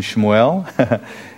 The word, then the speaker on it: Shmuel: And so we say Samuel and Sam, but there's really no A pronunciation in Shmuel.